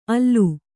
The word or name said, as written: ಆಲ್